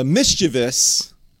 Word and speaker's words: mischievous: But, you know, I digress, because I am indeed the mischievous. That's how I'm going to start 2021, by pronouncing it correctly, apparently.